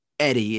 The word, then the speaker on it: Eddy: But Eddie is what I'm also called. How do I spell it? Do I spell it E-D-I-E or do I spell it E-D-D-Y? – E-D-D-Y.